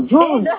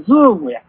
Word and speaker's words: ズーム: でも、ズームってちゃんと、いや、ズームじゃない。 – うん。 – な、ブリキさん、ズームや。わかります？ – イントネーションね。